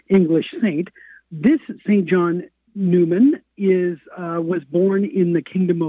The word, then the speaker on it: Neumann: So it's written Neumann, N-E-U-M-A-N-N. – Right. – Neumann.